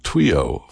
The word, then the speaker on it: Twio: His new album is entitled Twio, Volume 2. No, I did not mispronounce it. It's written Twio.